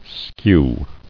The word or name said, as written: Skewing